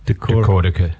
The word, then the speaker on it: Decortica: Decortica. – Decortica. Decortica. Rhymes with Metallica.